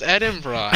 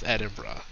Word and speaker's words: Edinburgh: It's pronounced Edinburgh.